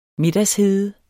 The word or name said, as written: middagshede